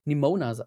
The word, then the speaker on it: Nimona: Im Englischen wird Nimora von ähm, Nimona heißt sie so.